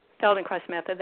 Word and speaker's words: Feldenkrais: And as I understand, you're also a practitioner, I guess, a teacher of the, help me say this correctly, the Feldenkrais method? – Feldenkrais method, that's correct. Yes.